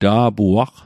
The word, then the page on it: ta'puaq: Mi'gmaq